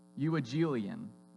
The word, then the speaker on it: Euangelion: Euangelion is how I pronounce it.